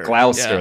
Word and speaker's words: Gloucester: How do you say Gloucester like that film? Isn't it Gloucester? – Oh, Gloucester. – Gloucester, yeah. That's it. Gloucester.